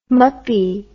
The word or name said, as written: muppie